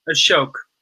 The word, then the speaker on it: Ashok: Ashok. – Ashok. – Like artichoke. That's what I've been telling everyone my whole life.